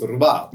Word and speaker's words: rubato: Sorry, it's Rubato.